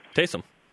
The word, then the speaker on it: Taysom: You know, I have so much respect, you know, and I'm probably not saying his name right, Taysom Hill, but... – You said it correctly, Taysom.